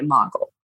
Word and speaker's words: mogul: Self-published, but she is a best-selling author and media mogul. Module? How do you say that? – Mogul. – Thank you.